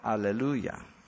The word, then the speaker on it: hallelujah: The H really is silent, hallelujah.